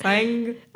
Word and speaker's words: Singh: Ha- Hari Singh. Hari Singh. Okay. Hari Singh. no, stop. Singh.